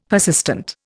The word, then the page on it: persistent: Indian